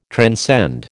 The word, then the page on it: transcend: American English